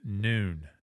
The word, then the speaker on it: Nun: Now, let's go to the slide that says N-U-N, nun, or noon. And I think people should pronounce it noon and I'm going to explain why.